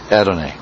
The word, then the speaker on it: Adonai: and always pronounced, the Hebrews, Adonai.